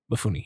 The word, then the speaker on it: Mifune: Is that how you Sanjuro, say his name? – Mifune, yeah.